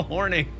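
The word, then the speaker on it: Hornung: Paul Horong. Probably pronounced that wrong. You know, yes, Paul Horning.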